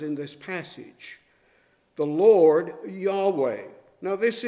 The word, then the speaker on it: Yahweh: Uh, the scholars have come pretty much to a consensus today that it is probably closer to Yahweh.